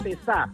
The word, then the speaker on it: pesá: La bolsa pesá, con acento en la A.